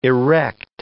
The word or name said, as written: erect